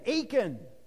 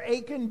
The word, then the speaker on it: Achan: One man, Achan, and I can remember Achan because it rhymes with bacon. Okay? Achan bacon.